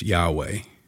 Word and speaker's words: Yahweh: When I say probably pronounced Yahweh, it's because ancient Hebrew was written without vowels, just consonants. And so all we have in the ancient Hebrew text are the consonants of God's name. And to transliterate those into English letters, it would be YHWH. Now, for reasons I'm not going to go into, most Old Testament scholars say that would be pronounced Yahweh.